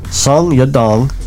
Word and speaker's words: Song Yadong: Song Yadong versus Casey Kenny in the Bantamweight division.